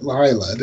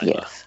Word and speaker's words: Leila: Lauren Lee Smith played Lila, but they pronounced it Lila, didn't they? – Yes, it was Lila. – Yeah. – But they spell it L-E-I-L-A, which I'd always pronounce.